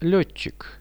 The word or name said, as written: letchik